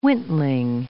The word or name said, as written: wintling